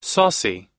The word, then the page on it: saucy: American English